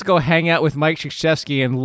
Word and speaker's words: Krzyzewski: Krzyzewski. Right. Yes. Okay. – Like that's how it's spelled out, because find a sh in there. There's no sh.